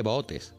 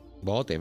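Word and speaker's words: Boots: Se escribe B-O-O-T-S y se lee botes. Alguien dirá, ¿se dice boot? No, botes, ¿verdad, Alberto? – Correcto, correcto.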